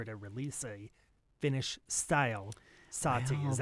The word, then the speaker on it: Sahti: If you can't hear the distinction in that pronunciation, the style name is Sahti, S-A-H-T-I. Your beer is Sahti, S-A-H-A-T-I.